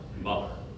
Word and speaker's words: Mahler: Mahler. first said it the American way, right? – Yes. – The American pronunciation.